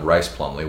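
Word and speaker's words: Rhys Plumlee: I know we've been saying it wrong. It's not Reese Plumley. It's John Rice Plumley, which we were corrected yesterday.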